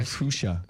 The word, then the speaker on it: fuchsia: Frisca. What is that? Frisca? – Oh, no, fuchsia. – Fuchsia. – Fuchsia. – Wow, I was way off on that one.